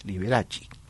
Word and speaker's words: Liberace: Agregó el candelabro, famosísimo candelabro, sobre el piano como un accesorio de firma y adoptó Liberace como su nombre artístico, señalando en sus comunicados de prensa que debía pronunciarse así, Liberace.